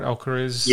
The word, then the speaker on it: Alcaraz: And just, you know, is it Alcaraz, if I pronounce his name right, Alcaraz? – Yeah.